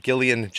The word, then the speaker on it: Gillian: And also it was Gillian Jacobs. It's with the hard G.